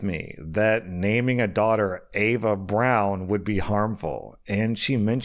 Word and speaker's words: Eva Braun: Also, OP clarifies, I actually didn't know this, but apparently in German, Eva Braun's name, I thought it was pronounced Eva Braun, is pronounced Ava Brown.